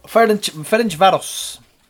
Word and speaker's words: Ferencváros: I think this was Maloney pointed this out, but the Hungarian team, how do you pronounce their name? Ferencváros. – Ferencváros.